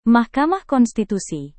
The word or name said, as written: mahkamah konstitusi